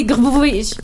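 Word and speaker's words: Grbovic: Mais c'est Grubovic.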